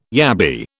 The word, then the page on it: yabby: British English